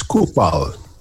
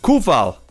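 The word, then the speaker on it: Coufal: Have you got it for me? – Yes, I have. Oh. It's Kufal. – Kufal. – Kufal.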